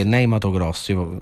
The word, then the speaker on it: Ney Matogrosso: che è Ney Matogrosso. Faccio la pronuncia così italiana. – Hai detto benissimo.